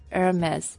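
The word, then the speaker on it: Hermès: 哎，但是那个爱马仕那个什么 Hermes，它那个 S 就是发音的呀。 – Yeah, that's kind of weird. I don't know why. It could be just that one exception. – 反正那东西那么贵，跟我一辈子也没关系。它标准的法语念法怎么念啊？爱马仕 Hermes。 – Hermes. – Hermes. The H isn't pronounced.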